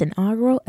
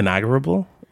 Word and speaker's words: inaugural: Is that how it's pronounced? – Inaugural. I thought so. – Like, have we just been, have, I've been mispronouncing it since I was a kid. – How have you been saying it? – Inaugurable? – There's no B.